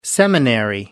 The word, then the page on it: seminary: American English